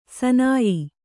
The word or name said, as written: ಸನಯ